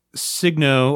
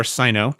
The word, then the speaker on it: Signo: And then I also have a Uniball Signo or Sino, depending on who you are, how you pronounce it.